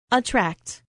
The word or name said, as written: attract